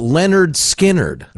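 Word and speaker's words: Lynyrd Skynyrd: Mott from Mott the Hoople, For Every Man, Jackson Browne, Aladdin Sane, David Bowie, pronounced Leonard Skinnerd.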